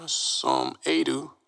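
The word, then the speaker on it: Som Aidoo: Instagram, SomAidoo, @ SomAidoo.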